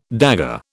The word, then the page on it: dagga: British English